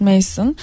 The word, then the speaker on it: Mason: Yazarı e, Daniel Mas Mason diye okunuyor herhalde. Evet Mason ve Seda Çingay Melor çevirisiyle yayımlanmış.